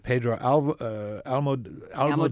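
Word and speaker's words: Pedro Almodóvar: And then you have Pedro Almodovar, is that how you say it? – Mm-hmm.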